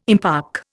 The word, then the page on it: impark: Indian